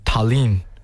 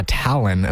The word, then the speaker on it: Tallinn: He said, Tallinn, something like that.